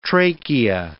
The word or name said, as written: trachea